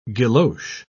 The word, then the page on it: guilloche: British English